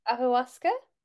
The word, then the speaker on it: ayahuasca: It's the Mexican, is it ahuasca, some kind of ceremony that you kind of drink something and you can. – Ayahuasca? – Yes, that's it.